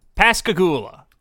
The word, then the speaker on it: Pascagoula: Pascagoula. The Pascagoula alien abduction. – Is that a place? Pascagoula. – Pascagoula, Mississippi.